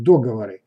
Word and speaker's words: договоры: Несмотря на то, что по новым нормам можно ставить ударение – и на первый слог договоры.